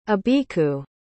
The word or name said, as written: abeeku